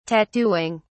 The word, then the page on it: tattooing: British English